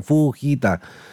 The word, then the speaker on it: Fujita: Escala Fujita, así tal cual se pronuncia, Fujita. Como japonés, Fujita.